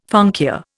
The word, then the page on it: funkia: Indian